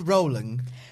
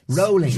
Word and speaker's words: Rowling: I believe it's pronounced Rowling. Rolling.